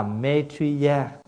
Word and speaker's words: Maitreya: Bởi vì Di Lặc á, là phát âm từ cái tiếng Phạn. Nếu nói cho đúng á, là Maitreya. Và chúng ta đã phát âm cái chữ Maitreya là A-Dật-Đa.